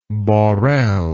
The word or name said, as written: Borel